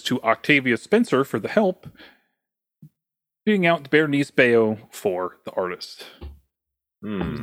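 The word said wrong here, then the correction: Bérénice: Well, I was going to say Berenice also, so good job. – Thank you. – Berenice.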